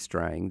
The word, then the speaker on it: Strang: It's my name, Steve Strang.